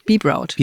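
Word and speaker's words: Bebrout: Englisch, Be Proud.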